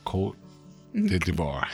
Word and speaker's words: Côte d'Ivoire: It's called Côte d'Ivoire.